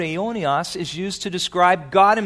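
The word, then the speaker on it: aionios (αἰώνιος): The word eternal is aionios. Say that. Aionios. And I'll tell you how to spell it in English. It's A-I-O-N-I-O-S. Aionios.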